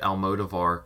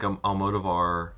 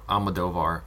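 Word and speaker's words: Almodóvar: And so I feel like Almodovar is a... – Woohoo! – Or Almodovar. I realize I've been saying it wrong the entire episode, guys. – You know. – Almodovar.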